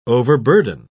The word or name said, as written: overburden